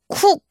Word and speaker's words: cook: 요거 발음할 때 cook 이렇게 우 이렇게 하지 마시고요. 약간 우리말에 으 사운드처럼 오히려 으 태극기 할 때 그 으처럼 발음해서 cook, cook 이렇게 하는 게 더 가까워요. cook 하지 마시고 cook. He's a cook.